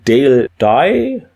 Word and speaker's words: Dale Dye: Ja, zu Dale Dye spricht man den so aus.